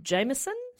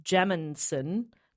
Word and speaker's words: Jameson: Says I've suggested before and always pronounces it like Jaminson, but it's Jameson.